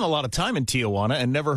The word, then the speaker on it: Tijuana: You say Tijuana. You put an extra sound before the wana. It is a three-syllable word, which you are pronouncing as four syllables.